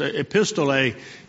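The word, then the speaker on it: epistole (ἐπιστολή): It's epistole is kind of the way you pronounce the Greek word.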